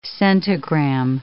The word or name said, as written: centigram